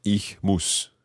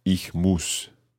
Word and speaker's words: muss: But ich muss. – Ich muss. – So there's a difference in the vowel sound there. Sie müssen, but ich muss. – Ü. And U.